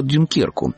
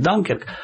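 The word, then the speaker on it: Dunkirk: Новый фильм Кристофера Нолана, который называется по-русски «Дюнкерк», а по-английски, наверное, правильно говорить «Данкерк», это одна из оптимистических историй, если это слово только уместно, Второй мировой войны.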